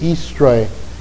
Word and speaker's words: Eastre: Actually, the correct pronunciation was Eastre, E-A-S-T-R-E, originally, and then later on became anglicized to Easter.